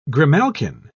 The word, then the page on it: grimalkin: British English